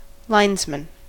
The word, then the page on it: linesman: American English